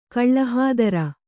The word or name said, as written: ಕಳ್ಳಹಾದರ